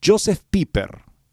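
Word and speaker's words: Josef Pieper: Hay un libro muy bonito que les recomiendo, Joseph Piper, Joseph Pieper se escribe.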